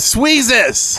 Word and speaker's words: Sweezes: The first, namely, is Sweezes. – Hey, where have we heard that name before? Oh, it rhymes with Jesus.